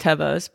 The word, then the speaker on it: Tevas: But Pickle chewed my, I call them Tevas. I know they're pronounced Tevas, but my tried and trues.